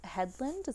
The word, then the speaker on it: Hedlund: I wonder if it's gonna look like her, cause like it's her and her boyfriend Garrett Hedlund.